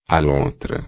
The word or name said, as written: lontra